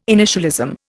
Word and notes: initialism: Indian

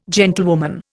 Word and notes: gentlewoman: Indian

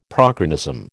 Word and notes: prochronism: American English

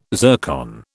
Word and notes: zircon: British English

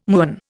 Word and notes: muon: Indian

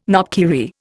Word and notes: knopkierie: Indian

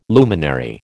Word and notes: luminary: American English

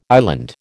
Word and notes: island: American English

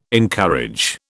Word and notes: encourage: British English

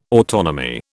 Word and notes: autonomy: British English

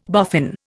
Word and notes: boffin: Indian

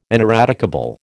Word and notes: ineradicable: American English

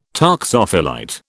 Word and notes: toxophilite: British English

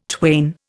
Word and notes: twain: Indian

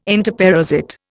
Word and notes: entoparasite: Indian

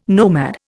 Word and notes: nomad: Indian

